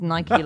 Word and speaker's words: Nike: I've checked the archive and then all we've got after that is Dan saying that Nike is pronounced Nike like Mikey.